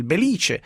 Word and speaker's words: Belice: Buongiorno sindaco, è così, è corretta la pronuncia? – Buongiorno a lei. – Belice. – Sì, diciamo sì, che anche è stato frutto del terremoto del 68, diciamo che oltre le case abbiamo perso anche l'accento.